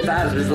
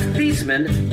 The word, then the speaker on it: Theismann: Joe Theismann's last name is actually pronounced Theismann, but a marketing guy at Notre Dame got him to change it to Theismann, so Theismann rhymes with Heisman.